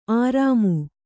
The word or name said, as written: ಆರಾಮು